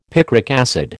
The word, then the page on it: picric acid: American English